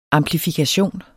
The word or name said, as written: amplifikation